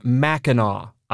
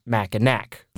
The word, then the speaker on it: Mackinac: takes place on Mackinac Island, not Mackinac, like we pronounced it, which, in our defense, is how it's spelled.